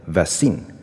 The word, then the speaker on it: vaccine: 第一个呢，学生往往会念成 vaccine。 vaccine 对吧？那我不知道你是不是这样念，嗯，如果这样念的话呢，我想提醒你们一下，我们那个重音应该放在前面，OK？